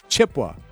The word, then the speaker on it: Chippewa: Well, Chippewa, pronouncing all syllables, is technically correct. If you are a local Chippewa Valley resident, you pronounce it Chippewa. Chippewa. – So it's not the Awa. It's Chippewa.